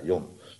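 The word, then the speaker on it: Yom (יום): want Yom, zeg ik het goed? Ja, Yom, dag heeft in de eerste plaats de betekenis van verlichting, hè?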